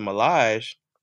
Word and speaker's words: Millage: Um, but when the brand came about, the Millage, I started saying that because people not only thought it was how you pronounce it,